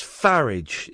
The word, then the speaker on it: Farage: It's pronounced like garage, so it's Farage. It's Nigel Farage is the actual correct way to pronounce it.